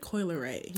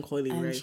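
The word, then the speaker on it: Coi Leray: But yeah, speaking of body type, actually, the whole Coil Ray, am I saying her name right? – I think it's like Coil Ray. Yeah, Coil Ray. Or Coily. I was saying Coily Ray for the longest.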